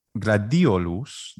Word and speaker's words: gladiolus: And the first word, gladiolus, the accent is on the letter I in Latin.